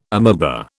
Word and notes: amoeba: British English

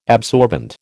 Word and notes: absorbent: American English

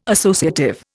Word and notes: associative: Indian

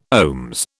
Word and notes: OHMS: British English

